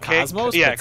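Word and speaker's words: KOS-MOS: Is it Cosmos? – Cosmos. It's K-O-S-M-O-S, but it's pronounced differently because Americans.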